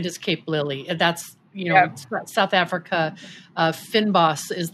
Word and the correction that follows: fynbos: Am I saying it right? – Fynbos. – Fynbos.